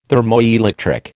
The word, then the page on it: thermoelectric: American English